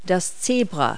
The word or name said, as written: zebra